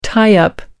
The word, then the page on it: tie up: American English